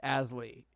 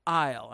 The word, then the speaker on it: aisle: And they're like, because it's not pronounced Asley, it's pronounced aisle.